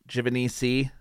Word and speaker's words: Givenisi: My name is Matt Givenisi. In case you were wondering, if you're new, that's how you say it. Or at least that's how I say it. A true Italian would slap me, as I say.